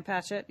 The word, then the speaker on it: Patchett: Second pick is Ann Patchell's new book, Commonwealth. – Oh, okay. Ann Patchett. Yeah. – Yep. Do I say her name right? Patchett. No, I forgot to cross my T's.